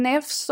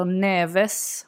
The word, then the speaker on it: Neves: Sen har vi ju eh, Ruben Neves också, eller Neves om man inte vill hålla sig till det portugisiska uttalet.